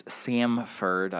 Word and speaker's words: Samford: Georgia hosts Samford.